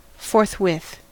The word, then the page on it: forthwith: American English